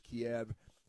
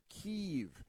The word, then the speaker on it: Kyiv: And then Democrats told us, media people, a couple of years ago, oh, no, now we call it Kyiv. And they say, oh, well, no, we didn't change the pronunciation. We've just had it wrong for centuries, and now we're going to start pronouncing it correctly. So we're going to call it Kyiv, like K-E-E-V-E or something.